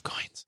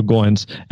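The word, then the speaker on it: Goins: He told me from the beginning that when we first met, his last name rhymes with coins. So Goines.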